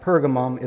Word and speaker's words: Pergamum: And today, we're going to be looking at verses 12 through 17, which is Jesus' church, letter to the church of Pergamum, or Pergamos, either pronunciation is correct. And this letter is one of rebuke. It's a call to repentance because Christ's church at Pergamum is engaged in a compromise, has begun to join itself with the world.